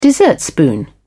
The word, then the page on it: dessertspoon: British English